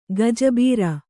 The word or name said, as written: ಗಜಬೇರು